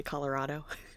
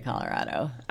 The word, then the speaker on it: Colorado: You say Colorado. – How do you say Colorado? – I say Colorado. – Oh. – With a rad. – A rad. Yes, Colorado.